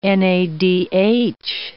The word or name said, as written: NADH